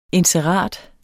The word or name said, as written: INSERATERS